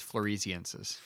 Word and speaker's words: floresiensis: I think it's floresiensis. – Floresiensis, thank you. It's spelt like floresiensis. I just can't read.